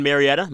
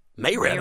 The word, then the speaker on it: Marietta: So you're in Marietta? How do you say it? – No, it's Marietta, if you're Marietta. – Being proper. And if you're from around there, it's Marietta. – Mayretta.